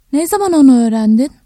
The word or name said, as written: ne zaman